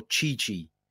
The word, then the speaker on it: Tite: So I wanted to look this up, and apparently you can either call him You can either call him Tite or Chichi. I'm not sure which one I'm going to go with. I'm going to go with Tite, which is how it's spelt and how I would say it, although I know that that's wrong, but I can't get my head around that pronunciation.